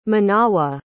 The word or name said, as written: Menawa